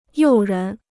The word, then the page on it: 诱人: yòu rén